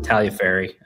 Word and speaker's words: Tagliaferri: The G is silent, Tagliaferri.